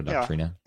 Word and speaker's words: Doctrina: I think you said Doctrina. Is that the better pronunciation? Sacra Doctrina. – Yeah.